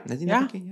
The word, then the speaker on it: Nadine Labaki: Huge news for the Lebanese filmmaker Nadine Labaki. Am I saying that right? – Yeah. Nadine Labaki. Yeah.